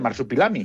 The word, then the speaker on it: Marsupilami: Pues para todo el mundo, Marsupilami, que no sé si lo he dicho bien, sí, ¿verdad? – En efecto. Uh-huh. Película, sí, sí, lo he dicho perfectamente, Marsupilami.